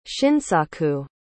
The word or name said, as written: shinsaku